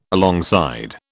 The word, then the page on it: alongside: British English